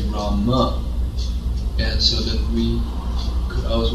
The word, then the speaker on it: Rama: So to say the name properly according to Sanskrit pronunciation, we should say Rama.